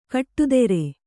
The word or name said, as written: ಖಡತರ